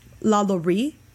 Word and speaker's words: LaLaurie: But yeah, there's so much history behind the LaLaurie Mansion, and I believe it's pronounced LaLaurie if you pronounce it appropriately, because it's a French name.